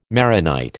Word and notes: Maronite: American English

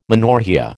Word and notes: menorrhoea: American English

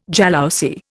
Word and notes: jalousie: Indian

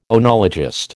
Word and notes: oenologist: American English

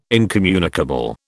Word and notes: incommunicable: British English

